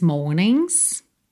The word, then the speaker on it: mornings: Or one of these mornings. E esse G de morning também não é pronunciado, você vai do N direto pro S.